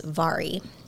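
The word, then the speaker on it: Mhairi: And then I had to look it up again, and it's pronounced Vari. So, so don't look for V, because you will not find it that way.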